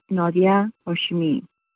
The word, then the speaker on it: Nadia Hashimi: Hello, my name is Nadia Hashimi. In Dari, the language of Afghanistan spoken by my family, my name would be pronounced Nadia Hashimi.